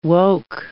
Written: woke

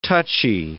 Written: touchy

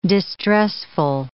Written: distressful

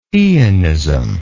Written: eonism

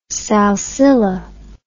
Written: salsilla

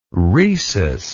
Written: rhesus

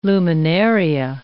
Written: luminaria

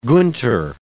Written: Gunther

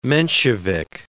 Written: Menshevik

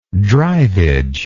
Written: drivage

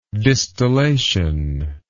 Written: distillative

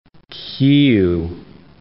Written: Kyyiv

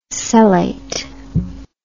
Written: cellate

